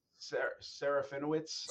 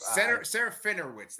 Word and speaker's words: Serafinowicz: Peter Safarinen? How do you say that guy's name? – Serafinowicz. – Serafinowicz.